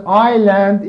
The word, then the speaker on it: island: S is mute. S is not pronounced. We say island.